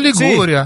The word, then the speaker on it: Liguria: Possiamo dire che in Liguria i vecchi vogliono sempre essere importanti? – Ma non solo in Liguria, in generale in Italia, devo dire. – Hai detto la Ligura? Non solo in Liguria.